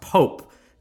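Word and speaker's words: POAP: And so I believe it's pronounced POPE, but it's which stands as proof of attendance protocol.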